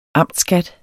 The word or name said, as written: amtsskat